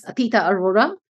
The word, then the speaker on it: Atita Arora: And I hope we can now say it correctly as Atita Arora. And I have no connection with the Northern Lights, just to clarify.